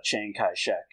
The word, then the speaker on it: Chiang Kai-shek: Like I said, in the manga, that is the bodyguard of Chiang Kai-shek, and I don't think he's supposed to be related.